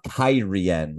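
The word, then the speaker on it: Cairhien: And Matt, being conscious of not giving away any of Rand's secrets, changes the conversation to the nation of Kyrian, which, yes, that's apparently how it's pronounced according to the television show.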